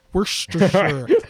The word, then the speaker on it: Worcestershire: You're like, Worcestershire. And you're like, no, that's right. And you're like, are you sure? Worcestershire. No, you're saying it right.